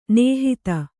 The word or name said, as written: ನಿಹಿತ